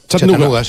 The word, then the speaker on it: Chattanooga: ¿Y se pronuncia así en Chattanooga? – Chattanooga, sí.